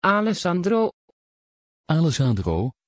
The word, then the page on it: Alessandro: Dutch